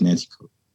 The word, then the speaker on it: Nanticoke: and all of the Nancy Cook or Nancy Cook, however you say, it, I forget how you say it. Like it's, it's a weird word, but I think it's Nancy Cook. N A N T I C O K E.